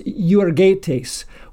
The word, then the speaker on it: euergetes: For example, there's a Greek word that's pronounced "euergetes," which literally would be translated "good worker."